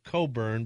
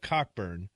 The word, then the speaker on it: Cockburn: Admiral George, well, he pronounced it Coburn, but the Americans pronounce it the way it's spelled, Cockburn.